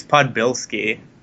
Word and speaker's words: Podbielski: That's usually how people pronounce it. It's Podbielski, but...